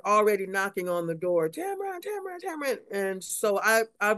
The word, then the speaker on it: Tamron: My name is Tamron. – Tamron, okay. – It rhymes with Cameron. That's my twin brother.